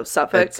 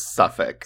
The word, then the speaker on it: Suffolk: I feel like that county is not said the way it's spelled, but sorry, so Suffolk. – I'm pretty sure it's Suffolk. – Suffolk, I think that's right. I keep trying to say the L, and I don't think you do.